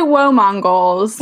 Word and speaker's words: WoMonGoals: First, we say "Whoa, Mongols." – Whoa, Mongols.